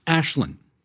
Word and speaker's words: Aislinn: And I want to spell this for you because her first name is spelled A I S L I N N, but it's not pronounced Aislinn. It's pronounced Ashlyn.